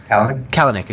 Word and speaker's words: Kalanick: Kalanick, excuse me.